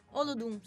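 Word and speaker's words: Olodum: che si chiama gli Oludum. – Che si chiama? – Gli Oludum. – Oludum, sì, Oludum. – Ok, tu mi correggi sempre sulla pronuncia.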